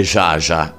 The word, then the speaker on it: Zsa Gabor: Certo, hanno ragione, perché noi tutti abbiamo sempre detto Zsa Gabor, la pronunciamo un pochino alla francese, mentre la pronuncia esatta sarebbe Zsa. Zsa Gabor. In America invece dicono Zsa.